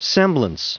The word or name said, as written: semblance